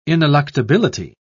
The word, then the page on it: ineluctability: British English